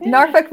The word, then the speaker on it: Norfolk: No, the Norfolk. I can't even say it like that. – Norfolk, Virginia. – Virginia, okay. – N-O-R-F-O-L-K. – Yeah.